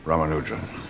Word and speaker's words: Ramanujan: Welcome to Trinity, Mr. Ramanujan. Am I pronouncing that properly? – Yes, sir. Perfectly. It's an honor, sir.